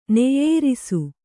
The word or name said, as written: ನೆಯ್ಯೇರಿಸು